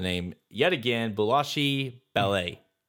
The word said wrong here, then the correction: Bolshoi Ballet: Bolshoi Ballet.